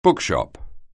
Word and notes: bookshop: anglais

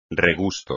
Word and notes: regusto: espagnol

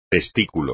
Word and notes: testículo: espagnol

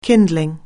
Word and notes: kindling: anglais

